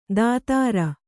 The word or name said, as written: ದಾತಾರ